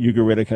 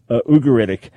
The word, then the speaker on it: Ugaritic: And by the way, growing up in New York, we pronounced it Ugaritic. I think the rest of the world, Ugaritic.